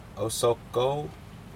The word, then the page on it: ousokou: Aneityum